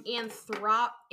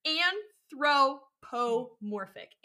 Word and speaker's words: anthropomorphic: Um, anthrop anth. Here we go. rap a wouldn't be a year if I didn't pronounce a word wrong. Anthrop. anthropomorphic.